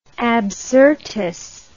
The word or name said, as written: Absyrtus